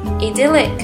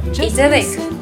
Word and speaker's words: idyllic: Idyllic 质朴宜人的，这个词有两种发音，idyllic 或 idyllic。